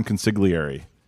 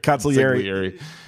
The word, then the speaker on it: consigliere: I don't think you pronounced that right, but yes, Dombrowski is the wartime consigliere. – Consigliere.